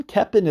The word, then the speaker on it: Koeppen: You can find me on YouTube, Rick Kepin. Kepin is spelled K-O-E-P-P-E-N.